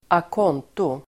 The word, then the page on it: a conto: [ak'ån:to]